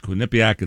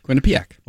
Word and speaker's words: Quinnipiac: We were talking about the Quinnipiac poll, and I always mispronounce Quinnipiac. It's Quinnipiac. – Quinnipiac. – Well, anyway, Lisa writes, I'm a 02 graduate of Quinnipiac, and my alums and I all pronounce it like Len does.